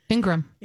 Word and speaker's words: Ingraham: Ingraham, is that how you say her name? – Ingraham. – Ingraham? – Yep.